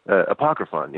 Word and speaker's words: Apocryphon: The cover art to Warp Riders and maybe even, sorry, I might mispronounce the name of the album here, but is it Acrophon? – Apocryphon, yeah. – Apocryphon, yeah.